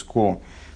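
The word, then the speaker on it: ко (קה): А, шалгевеско, ко – это божественное имя, первые две буквы четыре четырехбуквенного имени, юд, кей, вместо них произносится ко. Шалгевес ко.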